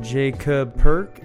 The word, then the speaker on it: Jaycub Perk: It's best to go to Instagram at Jacob Perk, and I'm spelling that J-A-Y-C-U-B-P-E-R-K.